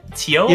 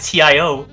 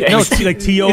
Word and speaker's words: TO: Is that how you say it? Teo? – Yeah. – I always said TIO. – No, like Teo.